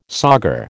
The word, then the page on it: sauger: American English